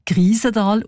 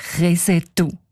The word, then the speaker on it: Krisental: Der Ueli Sagesser schreibt, ein älterer ortskundiger Mann habe den Namen allerdings nicht als Krisental ausgesprochen, sondern als Resetu.